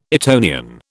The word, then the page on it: Etonian: British English